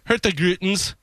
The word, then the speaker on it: Hurtigruten: The staff on Norwegian Cruise Liner, now I want to get this proper, Hurtigruten's ship. Yeah, that's right. That's how you pronounce it.